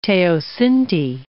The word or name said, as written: teosinte